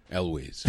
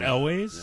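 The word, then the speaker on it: Elwes: And how do you pronounce his last name? – Elways. – Is it Elways? Yeah. I always thought it was Ewells, too.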